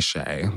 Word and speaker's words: Kyei: Shea is actually my middle name. It's spelled differently. It's Swahili. It's spelled K-Y-E-I. And it means beautiful boy. When I was picking drag names, I just thought that because everyone in my family calls me Shay.